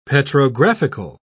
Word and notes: petrographical: British English

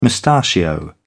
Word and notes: mustachio: British English

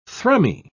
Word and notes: thrummy: British English

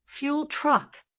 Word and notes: fuel truck: American English

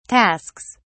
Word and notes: tasks: British English

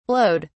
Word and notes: blowed: British English

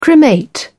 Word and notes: cremate: British English

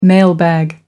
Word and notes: mailbag: American English

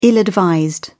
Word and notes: ill-advised: British English

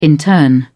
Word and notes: intern: British English

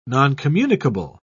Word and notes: noncommunicable: British English

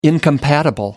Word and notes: incompatible: American English

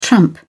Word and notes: tramp: British English

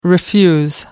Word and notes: refuse: American English

